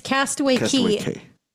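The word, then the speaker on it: Castaway Cay: Is it Key? – Okay. I know that it's Castaway Key. Key, according to Disney. They pronounce K as Key.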